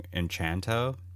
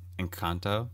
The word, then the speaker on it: Encanto: It's like, I got done reviewing Encanto. There I go. I pronounced it wrong again. Encanto.